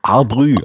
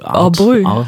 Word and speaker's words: Art Brut: Wir haben vorhin im Vorgespräch überlegt, wie man sie ausspricht. Du wolltest sie französisch aussprechen, ne? – Ja, wobei ich auch, ich kann ja kein Französisch, aber... – Hat er umso besser. – Brü. Arbrü. – Arbrü.